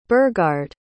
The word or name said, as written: Burghard